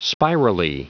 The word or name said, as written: spirally